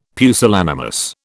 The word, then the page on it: pusillanimous: British English